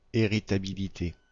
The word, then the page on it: héritabilité: /e.ʁi.ta.bi.li.te/; France